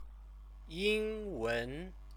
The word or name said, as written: yingwen